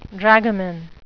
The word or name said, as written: dragoman